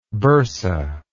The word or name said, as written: bursate